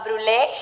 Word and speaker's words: Brulé: No, claro que sí, gracias a ti por venir, Marian, y de verdad denle un vistazo a Brulé GDL, con acento en la E, recuerden, y enamórense de las fotos y que se les antojen los postres.